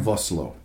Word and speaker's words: Vosloo: Vosloo.